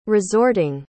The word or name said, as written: resorting